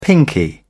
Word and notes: pinkie: British English

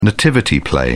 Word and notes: nativity play: British English

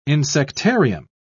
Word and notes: insectarium: British English

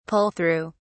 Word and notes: pull through: British English

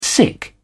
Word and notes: sic: British English

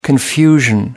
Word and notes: confusion: American English

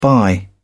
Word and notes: bye: British English

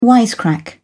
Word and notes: wisecrack: British English

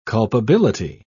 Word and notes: culpability: British English

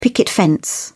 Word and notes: picket fence: British English

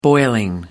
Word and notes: boiling: British English

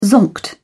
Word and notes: zonked: British English

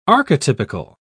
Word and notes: archetypical: British English

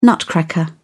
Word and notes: nutcracker: British English

